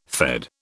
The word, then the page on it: Fed: British English